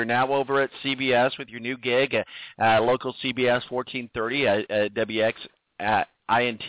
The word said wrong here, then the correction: WXNT: Did I say that right? WXNT. I'm sorry. – XNT. That's it.